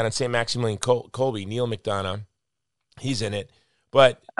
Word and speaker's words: McDonough: Is it McDonough? McDonough? – McDonough. – Neil McDonough. Neil McDonough.